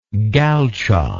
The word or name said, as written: galcha